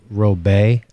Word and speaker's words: Robett: Robet, R-O-B-E-T-T is how they spell it.